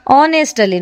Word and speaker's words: honest: ಆನೆಸ್ಟಲ್ಲಿ ನೋಡಿ, H silent ಇದೆ, HONEST, ಆನೆಸ್ಟ್ ಅಂತ ಪ್ರೊನೌನ್ಸ್ ಮಾಡಬೇಕು.